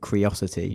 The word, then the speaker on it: Qriocity: It's spelled Q-R-I-O-C-I-T-Y. – Everyone is going to pronounce it Curiosity.